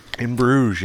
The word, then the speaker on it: Bruges: In Bruges is how you pronounce it, right? – In Bruges, yep. – Yep. Even though there's an S on the end.